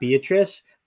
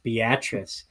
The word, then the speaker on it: Beatrice: So folks that aren't from Nebraska, it's spelled just like Beatrice, but in Nebraska, it's pronounced Beatrice.